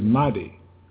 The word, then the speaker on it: Mahdi: Islam has preserved the correct pronunciation of that word that they translated Medes, the word Mahdi.